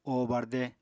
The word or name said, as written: o barde